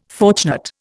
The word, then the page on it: fortunate: Indian